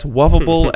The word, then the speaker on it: Wovable: and that's Wovable as in, like, lovable, but with a W.